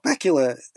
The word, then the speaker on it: Bakula: Now, we just had Scott Bakula say where you are and what you're listening to. And Bakula rhymes with Dracula, which leads us to Dark Shadows.